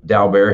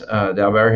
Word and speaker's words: Daubert: We have to have, you know, what's known as a Daubert hearing, or some people may say, pronounce it a different way other than me, but that's how we say it in Texas, Daubert hearing, to get all the experts on each side to testify.